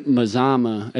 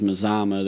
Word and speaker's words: Mazama: Yeah, they say the Mazama is Mazama. So I'm pretty sure that's how I'm going to pronounce it, is Mazama.